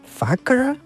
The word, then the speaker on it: Fakhr: Y hoy vamos a tener un monográfico, un programa monográfico, espero que sea mono, dedicado al cantante Roger Fakhr, escrito F-A-K-H-R, no sé cómo se pronuncia, Fakhr, algo así, Fakhr seguramente, espero que sea así.